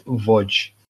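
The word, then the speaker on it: Łódź: They were like, I was like, yeah, I saw this sign on the way down, and it's spelled Lods. And they were like, Lods. Wodz. I was like, yeah, I've heard it pronounced Wodz or Wodz.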